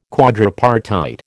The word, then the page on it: quadripartite: American English